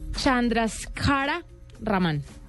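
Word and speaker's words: Chandrasekhara Raman: El nombre es Chandraskara Raman. – Muy bien, eh, le quedó, bien la pronunciación.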